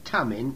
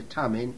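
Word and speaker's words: Thummim: Also, put the Urim and the Tummim, it's a silent H there, the Urim and the Tummim in the breastpiece, so they may be over Aaron's heart.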